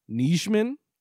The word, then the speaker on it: Nijman: It's Yosh Nijman. You do pronounce the J.